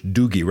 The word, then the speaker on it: Dougie: This gentleman's got a song called Rite of Passage, and his name is Dougie MacLean. I think it's pronounced more in Celtic language, Dougie rather than Dougie, but it's spelled Dougie, D-O-U-G-I-E.